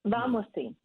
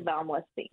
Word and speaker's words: Valmoisine: The Domaine de, I'll probably say it wrong, Villamosine? – Valmoisine. It's okay. Like limousine, but with a V. Domaine de Valmoisine.